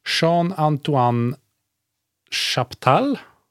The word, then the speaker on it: Jean-Antoine Chaptal: Jag ska försöka uttala det här då. Jean-Antoine Chaptal. – Det är jättebra.